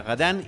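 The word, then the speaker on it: Radin: El título original, como mencionó eh, Diana, Diana es bueno, se escribe Radín, uno quizá diría Gadán y la traducción literal significa avaro.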